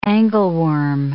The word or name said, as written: angleworm